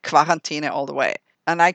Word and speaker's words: Quarantäne: Is it Quarantäne or Quarantäne? – Oh, I'm 100% Quarantäne. That's where I'm coming from.